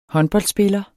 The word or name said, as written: håndboldspiller